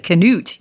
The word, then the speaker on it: Canute: And I also want to know how to pronounce the name of it. Canute, yes. – Canute? It sounds Canadian, but it's not.